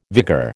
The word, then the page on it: vigour: American English